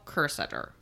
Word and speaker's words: Kerstetter: Anyway, this is the case of Dale Kerstetter. – I think that's how you pronounce it. – Yes.